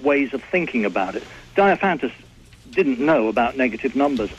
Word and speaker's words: Diophantus: Diophantus. – Diophantus.